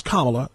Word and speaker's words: Kamala: And by the way, her name is pronounced Kamala. Kamala. Kamala like communist, not Kamala like capitalist.